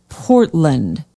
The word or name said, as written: portland